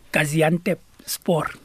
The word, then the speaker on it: Gaziantepspor: Gaziantepspor.